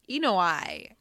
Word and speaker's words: ENOi: I had to look it up. You did say it right. It is just E-N-O-I. – Oh, and you just say all of the words. Yeah. E-N-O-I. – E-N-O-I.